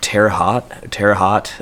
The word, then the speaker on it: Terre Haute: I'm going to butcher the pronunciation, but Terre Haute, Indiana? – I think that's it.